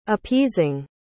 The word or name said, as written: appeasing